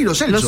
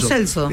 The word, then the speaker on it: Lo Celso: Y Giovanni Lo Celso se presenta como Giovanni Lo Celso. – Lo Celso. – Y claro. Yo dije, ¡apa! – En fin. – Lo venía pronunciando mal.